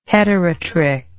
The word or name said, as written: heterotrich